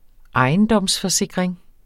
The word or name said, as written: ISNET